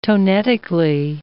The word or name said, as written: tonetically